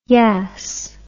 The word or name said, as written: yas